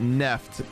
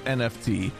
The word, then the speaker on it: NFT: Oh, before we go, The Verge wants you to pronounce it Neft instead of NFT. The Verge is wrong. I'm sorry. You're just dumb. It's NFT.